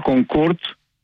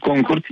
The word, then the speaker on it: Concorde: Είναι Concorde και όχι Concorde για ένα λόγο. Ήταν γαλλοβρετανικό αεροπλάνο, αλλά οι Γάλλοι θέλουν να έχουν πάντα το προφέρι, το όνομα προφορά.